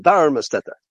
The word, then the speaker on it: Darmstadter: Accent on the first syllable. Darmstadter.